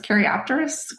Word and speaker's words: Caryopteris: Is it Caryopteris? Am I saying that correctly? – Yes, it's Caryopteris Grand Blue.